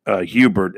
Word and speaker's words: Herbert: I kind of, to be honest with you, I breathed a sigh of relief when the Chargers picked Justin Herbert at number 6. I mean, Herbert. Justin Herbert, Herbert kind of reminds, kind of sets my mind to a rhyme. Herbert rhymes with Gabbert.